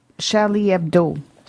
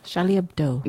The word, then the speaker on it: Charlie Hebdo: Oh, Charlie Hebdo. – Charlie Hebdo.